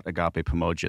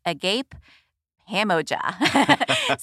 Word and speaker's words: Agape Pamoja: So Agape Pamoja, first of all, thanks for pronouncing it correctly. I can't begin to tell you how many times I go to a store and they see us on tax exempt and they go, Agape Pamoja.